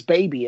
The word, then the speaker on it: Baby: My parents, we pronounce it Bobby for the longest time, and I never really understood why. And I was like, wait a minute. You know, I got to high school, and I was like, you know, this is kind of weird. I'm pretty sure it's Baby. And sure enough, you know, in India, that's how they pronounce it. And I never really had the conversation with my parents as to why they pronounce it that way.